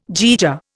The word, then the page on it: jaeger: Indian